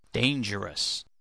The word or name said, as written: dangerous